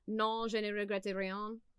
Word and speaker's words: Non je ne regrette rien: She took French in high school and college before I say this, but the French song Non je ne regrette rien sounds good to me.